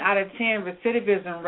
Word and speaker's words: recidivism: How do you say that one? – It's recidivism, and what it basically means is for a person who has been repeatedly incarcerated.